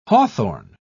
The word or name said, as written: hawthorn